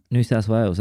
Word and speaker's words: New South Wales: Here with me in person today at Bulletproof Labs Alpha, up at my house on Vancouver Island, all the way here from New South Wales, Australia. Wales? Is there some proper way to say that? – New South Wales, that was good pronunciation.